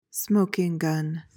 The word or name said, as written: smoking gun